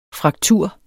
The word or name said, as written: fraktur